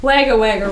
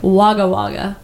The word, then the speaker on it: Wagga Wagga: Apparently, we said wagga wagga wrong. – Well, of course. How do you say it? – Nader, but it is spelt like Wagga Wagga, but apparently it's Wagga Wagga.